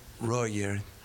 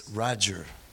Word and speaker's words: Roger: Cuando un chileno pronuncia mi nombre, Roger, como norteamericano, este me llama la atención, que es Roger.